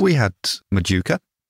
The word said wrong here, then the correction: Mujica: Who? – If that's how you pronounce his name. – Rafa Mujica. – Yes, Mujica, him.